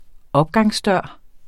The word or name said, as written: opgangsdør